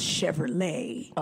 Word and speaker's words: Chevrolet: I think it's pronounced Chevrolet. – Oh. – But I'm going to let you... – You know I be mispronouncing things, so I'm just going to keep it as Chevrolet.